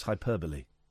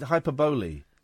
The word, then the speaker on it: hyperbole: By the way, the pronunciation of the word is hyperbole. That annoyed me tonight. – Yeah, me. – The John Ronson thing was great. And then someone asked a question. They said hyperbole.